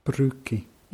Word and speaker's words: брюки: Brücke. – Ja, dieses Brü wird wie in dem Wort, in dem deutschen Wort Brücke ausgesprochen. Brücke.